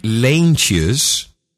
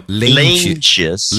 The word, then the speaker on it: Leentjes: okay, it's time for an, an, an, official no agenda Dutch pronunciation moment. Jeff, Je- Jeffrey Leentjes uh-huh. Say, it, say it with me now. – Leentjes. – Leentjes, very good.